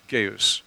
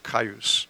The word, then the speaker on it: Gaius: Gaius, or Caius, however you would like to pronounce it. It's proper any of these ways.